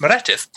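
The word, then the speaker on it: Meredydd: As long as you know that a Y is pronounced I and a double D is pronounced th. So it's Meredith.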